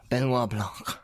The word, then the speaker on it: Benoit Blanc: Benoit Blanc.